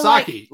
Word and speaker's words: Psaki: Yeah, so Psaki. You don't pronounce the P. – Psaki? – Yeah, you don't say Psaki. It's just Psaki.